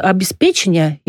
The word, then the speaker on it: обеспечение: Это обеспечение и обеспечение, и одновременно, и одновременно. – Обеспечение только в литературном языке.